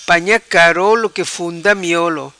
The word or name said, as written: Panha carolo